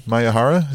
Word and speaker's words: Miyahara: Kento, I don't know how to say this name, Miyahara, is it? – Yeah.